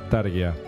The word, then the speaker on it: Tarja: No, para mí que le dieron el gusto a Tarja, dijeron, o Tarja, como se pronuncia. – Targia. – Targia.